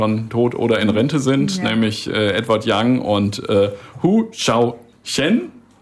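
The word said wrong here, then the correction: Hou Hsiao-hsien: Hou Xiaoxian.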